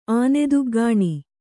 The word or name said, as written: ಆನೆದುಗ್ಗಾಣಿ